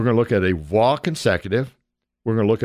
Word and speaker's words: waw: And in the Hebrew language, that word is vaw, spelled W-A-W. The first W is a soft V, so we pronounce it vaw.